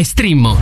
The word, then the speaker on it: Streammo: Si scrive Streammo o scarica l'app.